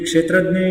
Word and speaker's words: क्षेत्रज्ञ: क्षेत्रज्ञ संस्कृत में और हिंदी में क्षेत्रज्ञ ऐसे वो लोग उच्चारण करते हैं। इट शुड बी क्षेत्रज्ञ इन Hindi also, sure you are sure, you should go to the root of the root history of the language actually.